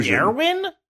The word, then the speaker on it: Keoghan: Keoghan.